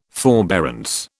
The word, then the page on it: forbearance: British English